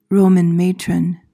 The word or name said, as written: Roman matron